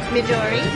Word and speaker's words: Midori: Midori. – Midori? – Uh-huh. M-I-D-O-R-I. – And your name, sir? – Sava, S-A-V-A.